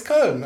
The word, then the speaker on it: Köln: Very good. Köln. Yep. It's almost perfect. Come on. – Tell him that, you know, when he makes a mistake, tell him. – Right. – No, it's Köln. It's great.